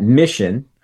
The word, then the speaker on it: MICHN: Our acronym is MICHN, and we pronounce that mission.